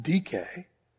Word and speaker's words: Dike: Her name transliterated into English would be spelled D-I-K-E. We'd probably call her Dike. It was pronounced DK, which actually rhymes with Nike, which is actually pronounced Nike.